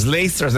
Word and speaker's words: lacers: Lacers. Now give them their pronunciation. – Lacers. We're going to mispronounce it. Let's mispronounce it correctly. – Yeah.